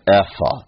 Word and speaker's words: ephah: It's really an ephah, if you pronounced it according to the Hebrew.